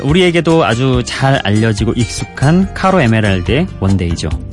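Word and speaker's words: Caro: 저는 근데 이 카로라는 그러니까 C로 시작하는 발음을 카바니라는 뭐 축구 선수도 까바니가 되고 왠지 저는 쌍기역으로 발음하게 되더라고요. 습관처럼. 그래서 카로 에메랄드보다는 까로 에메랄드 이렇게 발음하게 되더군요.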